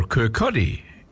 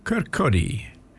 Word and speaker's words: Kirkcaldy: 11 Kirkcaldy Street, Bathurst. A 2MCE sponsor. – Or Kirkcaldy, if you care to pronounce it the way the people in Fife do. Kirkcaldy.